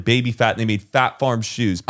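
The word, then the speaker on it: Phat Farm: Fat, no, you got the name wrong. Fat Farm. It's Fat Farm. – It's Fat Farm? – P-H-A-T. Fat Farm. Oh. And it had that P with like the sound.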